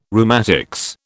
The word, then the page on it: rheumatics: British English